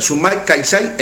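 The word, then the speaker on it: Sumak Kawsai: Te lo deletreo, S-U-M-A-K, acá. – Sí, Sumac. – Sumac, K de kilo, A-W-S-A-I, Calçay. Así se pronuncia. Sumac Calçay, en lengua cacán, quiere decir buen vivir.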